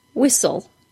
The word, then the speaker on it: whistle: Today's topic is the silent T in words like listen and whistle. That's right, those T's are absolutely silent. Don't say them. There are two different spelling patterns here to be aware of. The S-T-E-N pattern, as in the word listen, and the S-T-L-E pattern, as in the word whistle.